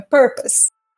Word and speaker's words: purpose: O, Lula que, o Lula que me deu essa dica, porque ele falava uh, é, purpose. A ênfase dele era no pose. Né? E aí ele, ele entendeu que não, que era na primeira sílaba, então é purpose.